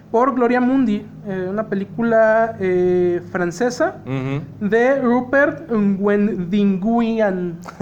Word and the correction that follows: Robert Guédiguian: Ah, cabrón, ¿sí se dice, güey? – Robert Guédiguian.